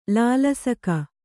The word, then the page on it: ಲಾಲಸಕ: kannada